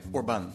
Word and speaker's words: Orbán: Hoe spreek je het eigenlijk uit? Orban of Orban? Ik zeg Orban. Orban, doe ik dat ook.